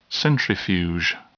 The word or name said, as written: centrifuge